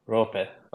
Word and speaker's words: Roope: So I'm a Finn, so in Finland we say Roope with an O. So you can think of how you pronounce then Robert, and you just leave out the earth at the end. So it's the same O sound. So Roope.